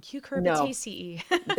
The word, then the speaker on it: cucurbitaceae: That hybrid, and especially in the squashes and cucumber groups, the cucurbitaceae. That's a very hard word. – It's a cucurbitaceae. – It's that middle syllable that just kills me.